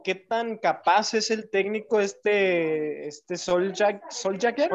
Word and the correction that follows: Solskjaer: Oleg, Oleg Solskjaer.